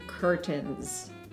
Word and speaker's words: curtains: И поэтому да, то, что они это коверкуют, э, произносят неверно, я их понимаю, но это все-таки curtains. – Вот. Я, ну, собственно говоря, да, и поэтому AI читается как «э», потому что французская штука.